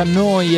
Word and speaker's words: Neu: Neuradio.it si scrive Neu, si pronuncia Noi e vuol dire nuova, nuova emittente urbana in diretta da Bologna.